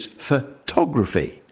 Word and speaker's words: photography: Now, photo is all right, but then if you listen to the first syllable, it's not photo in, but it's photography, when the stress shifts one syllable to the right.